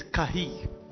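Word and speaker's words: Kahi (καί): So the word there and is the word Kai, is spelled K-A-I in Greek. The interpretation, the pronunciation is Kahi. Alright? Unbelievable, isn't it? But that's how they pronounce it. In English, we would just say Kai. And also I would say Kai. But this said is Kahi.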